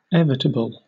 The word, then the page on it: evitable: British English